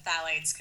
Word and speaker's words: phthalates: Okay, well, I'm glad you said phthalates,